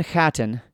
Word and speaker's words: Chattan: If you don't, if you're new to the subject, Clan Chattan. So I'm pronouncing that with a guttural ch because that's the I think the I'm just doing my best.